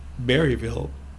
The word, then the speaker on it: Berryville: But growing up in a small town, I mean, we were Berryville, which rhymes with Mayberry.